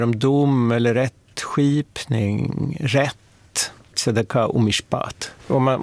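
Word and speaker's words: Tzedaka (צדקה): Och det viktigaste hebreiska ordet, det är tzedaka. – Tzedaka? – Ja. Anneli kanske vill uttala det lite bättre än vad jag gör. – Tzedaka.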